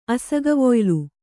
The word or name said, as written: ಅಸಗವೊಯ್ಲು